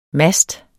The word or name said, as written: master